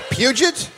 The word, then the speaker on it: Puget: Puget. I said it right. – Puget.